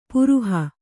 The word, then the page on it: ಪ್ರಹಿ: Kannada